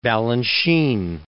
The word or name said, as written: Balanchine